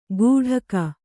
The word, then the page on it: ಕುಟಕ: kannada